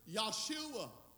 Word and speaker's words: Yeshua: The Hebrews pronounce it Yeshua. The Greeks call it Iesus. The Spanish call it Jesus.